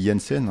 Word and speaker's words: Jensen: Je pense qu'on dit Jensen. Hein. Ça s'écrit J-E-N-S-E-N.